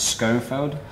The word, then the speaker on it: Schoenfeld: I, I would pronounce it Schoenfeld. Harvey said Schoen.